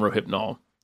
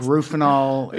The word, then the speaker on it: Rohypnol: So in March, they were in Rome, and he overdosed on Rohypnol and wrote a suicide note. – Which no one can pronounce in the movie. No one says Rohypnol correctly. – Yeah, they call it like Rufinol.